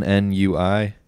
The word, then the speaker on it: ennui: Ennui? – Uni.